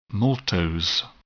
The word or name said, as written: maltose